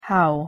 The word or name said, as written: how